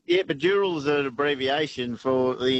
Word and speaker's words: Dural: It's spelled D-U-R-A-L, so that's Dural. That's the term that it, you know, colonised, but it actually stands for D-O-O-R-A-L. That's twice Dural, which is burning log.